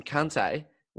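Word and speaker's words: Kanté: Well, the way I'd answer that is the reason why I would always pronounce him Kante was because he was there obviously at the same time as Conte, their manager.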